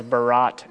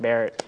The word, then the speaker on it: Barrett: Well, I told you, I thought, like, whenever I first read that, that was Barat, and I thought you were, like, some Middle Eastern man. And then, like, I'm looking for... And then Pastor Brian, like, said it's pronounced Barrett.